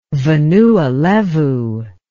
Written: Vanua Levu

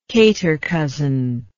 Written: catercousin

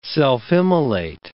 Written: self-immolate

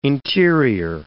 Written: interior

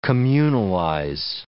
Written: communalize

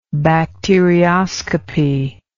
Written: bacterioscopy

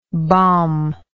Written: balmlike

